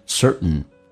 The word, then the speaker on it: certain: 来看一下这句话的难点， Just because, because 跟 a 叫做 because, 而且它用降调读，你不用去尝试听到每一个细节，就变成 just because, just because a certain 这个单词在口语化当中 t 跟 n 组合，美式发音经常 t 不发音，就气流阻塞一下，就变成 certain someone Someone 跟 is 跟 also 他们之间都有连音，也会造就你听力的一个难点，就变成 someone is 跟 also 叫 is also, 就变成 someone is also working after hours.